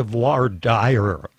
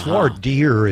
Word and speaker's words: voir dire: I have to say, having taken French in school for 8 years, I strenuously object to voir dire. It's voir dire, I believe, is the correct pronunciation.